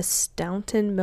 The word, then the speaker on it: Staunton: When he was 14 years old, his father encouraged him to enroll at the Staunton Military Academy in, I guess that's how you pronounce it. It's like S-T-A-U-N-T-O-N, yeah. Staunton, Virginia, for 2 years.